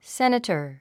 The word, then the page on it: senator: sénətə r; セネタァ